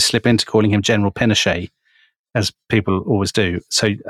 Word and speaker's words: Pinochet: But we respect Chilean pronunciation, yeah. So it will be General Pinochet.